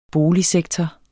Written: boligsektor

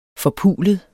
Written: forpulet

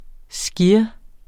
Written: SKYREN

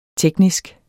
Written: teknisk

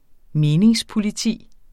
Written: meningspoliti

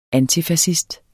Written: antifascist

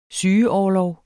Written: sygeorlov

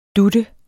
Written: dut

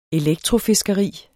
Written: elektrofiskeri

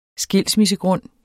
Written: skilsmissegrund

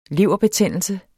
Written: leverbetændelse

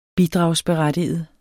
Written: bidragsberettiget

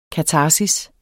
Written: katarsis

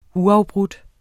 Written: uafbrudt

